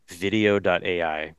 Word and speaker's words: Vidyo.ai: There's a service called video.ai, which is spelled VIDYO.ai.